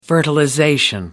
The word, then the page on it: fertilization: British English